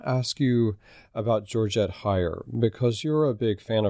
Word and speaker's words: Heyer: First of all, just so you know, the Brits pronounce it Heyer. – Heyer, okay. – Yeah, I always pronounced it Heyer, too, and then when I was in England, got firmly corrected.